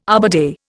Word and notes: Arbor Day: Indian

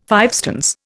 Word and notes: fivestones: Indian

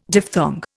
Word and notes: diphthong: Indian

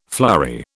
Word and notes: flurry: British English

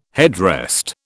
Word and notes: headrest: British English

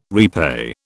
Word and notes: repay: British English

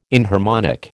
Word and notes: inharmonic: American English

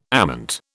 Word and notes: ament: British English

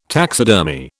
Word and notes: taxidermy: British English

